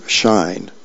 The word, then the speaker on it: SHEIN: Just over a year ago, Chinese ultra-fast fashion retailer Shine, that's spelled S-H-E-I-N, it began leasing 37 acres of warehouse facilities.